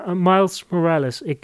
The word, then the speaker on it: Miles Morales: Dat is ene Miles, zeg ik dat goed? Jij bent helemaal, jij bent meer thuis in de Spider-Man en de superheldenfilms misschien dan ik. – Ja, Miles Morales.